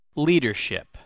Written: leadership

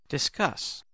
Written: discuss

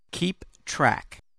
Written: keep track

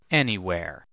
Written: anywhere

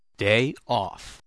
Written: day off

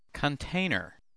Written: container

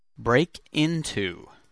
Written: break into

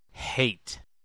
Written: hate

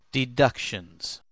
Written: deductions

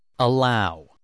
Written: allow

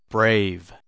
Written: brave

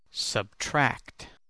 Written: subtract

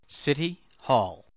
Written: city hall

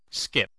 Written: skip

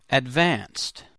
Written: advanced

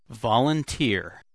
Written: volunteer

